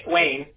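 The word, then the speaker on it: Waian: It's W-A-I-A-N. – Oh, I'm going to butcher that. Wayne? Wayne? – Wayne. – Wayne? Oh, okay. It just sounds like Wayne, but spelled fancy.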